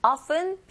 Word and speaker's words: often: Often, 빈도부사죠. 그러니까 내용어. Accompanies 동사, pain. 이렇게 되니까. 결국은 it을 제외한 나머지가 모두 다 내용어입니다. It open보다는 open 하시면 안 되고 often, often, F 발음 들어주셔야 돼요. P가 아닙니다.